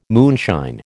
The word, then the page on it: moonshine: American English